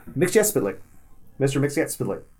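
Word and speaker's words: Mxyzptlk: and corrects him on the pronunciation of his name, and says that it is saying these four words is how you say his name, and it's Mr. Mix, Yes, Spit, Lick.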